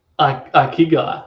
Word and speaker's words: Ikigai: Ikigai, I believe so.